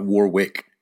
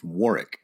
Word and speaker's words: Warwick: But during that, he goes out of his way to say that his name is pronounced Warwick, and you don't say the second W. It's not Warwick, it's Warwick.